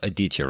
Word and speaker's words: Aditya: And I think it's pronounced Aditya, right? Did I put the stress on the right syllable there? – That's how I've been pronouncing it. But okay.